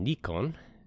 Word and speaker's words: Nikon: All right, for our opening topic, the camera company Nikon, or I think in Japanese it's pronounced Nikon, is going to end development on their high-end DSLR cameras.